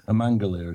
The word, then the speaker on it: amygdalae: It's amygdalae.